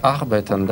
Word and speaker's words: arbeite: Опять наше любимое arbeiten, да? Ich arbeite gern. Nein, ich arbeite nicht gern. Ударение сохраняем на первом слоге.